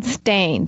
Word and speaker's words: Stayne: And Stain is spelled Stayne for people. It's Damien Stain.